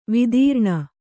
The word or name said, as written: ವೈತರಣಿ